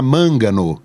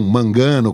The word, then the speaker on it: Mangano: Silvana Mangano, e não Mangano, como a gente falava.